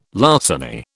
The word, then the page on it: larceny: British English